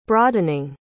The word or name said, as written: broadening